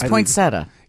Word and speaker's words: poinsettia: It's poinsettia. – It's poinsettia. – Legally, poinsettia. – Legally, it's poinsettia. – Yeah. – But it's just that extra syllable in there.